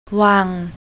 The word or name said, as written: wang